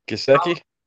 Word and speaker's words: Gesicki: I never say his name right. – Gesicki? – Yeah, thank you.